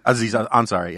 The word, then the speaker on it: Aziz Ansari: Aziz Ansari, yeah.